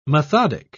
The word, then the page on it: methodic: British English